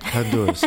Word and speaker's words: Honduras: And then when Miss Honduras, did I say it right? – Honduras, right.